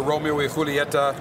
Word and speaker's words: Romeo y Julieta: And I know that you've repackaged the Romeo y Julieta.